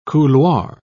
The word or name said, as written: couloir